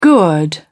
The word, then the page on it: gourd: British English